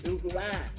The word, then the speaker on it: Hawaii: Hawaii. That's how you say Hawaii.